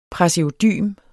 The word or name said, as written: praseodym